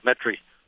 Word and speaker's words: Metairie: It's Metairie. The locals pronounce it Metairie.